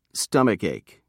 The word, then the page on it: stomachache: British English